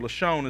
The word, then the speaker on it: Lashon: Lashon is how we would pronounce it.